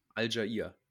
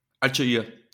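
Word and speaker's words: Allgeier: Allegary, wie spricht man ihn aus? Al-Jair. Al-Jair, danke.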